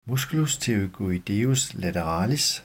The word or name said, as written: m. pterygoideus lateralis